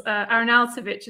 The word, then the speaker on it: Arnautovic: Arnautovic is how you say it, but you did pretty good.